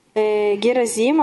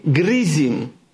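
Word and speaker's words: грызим (גריזים): Тогда гора Герозим, она будет действовать в твоей жизни. – Каха никра гар грызим. – Так называется гора Герозим на иврите. – Ло гаразим, ло герозим, грызим. – Она произносится как грызим.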